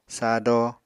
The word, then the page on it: sado: apatani